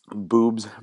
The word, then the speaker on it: Bubs: So I want to give a shout out to all the people from Boobs Brewing Company. Now that's spelled B-U-B-S in Winona, Minnesota.